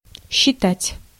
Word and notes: estimer: [ɛs.ti.me]

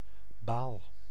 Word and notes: baal: [baːl]

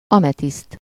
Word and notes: ametiszt: /ɒ.mɛ.tist/

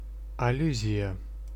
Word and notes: аллюзия: /ɐˈlʲːʉzʲɪjə/

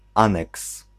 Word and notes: appendix: /əˈpɛn.dɪks/

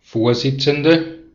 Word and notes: chair: [tʃɛɹ]; [tʃæː]; /tʃɛə(ɹ)/; /tʃɛəɹ/; /ˈtʃɛə/

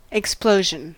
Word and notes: explosion: English

